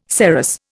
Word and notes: saros: Indian

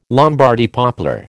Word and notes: Lombardy poplar: American English

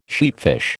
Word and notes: sheatfish: American English

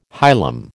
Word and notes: hilum: American English

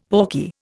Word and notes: pawky: Indian

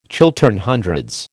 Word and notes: Chiltern Hundreds: American English

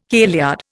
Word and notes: kailyard: Indian

